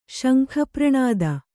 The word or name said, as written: ಶಂಖಪ್ರಣಾದ